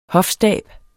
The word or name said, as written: hofstab